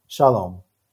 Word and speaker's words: Sholom: Did I say it right? – Nope, but that's okay. – Oh my gosh. What the heck? So you say it. – Shalom. – Shalom. What the heck? How am I saying it wrong, man? – I don't know. People put emphasis on the wrong syllable, and it's okay.